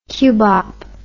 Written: cubop

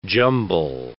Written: jumble

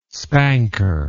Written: spanker

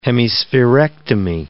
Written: hemispherectomy

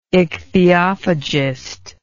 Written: ichthyophagist

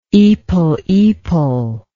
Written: ipilipil

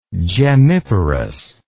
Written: gemmiparous

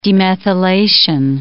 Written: demethylation

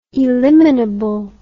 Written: eliminability

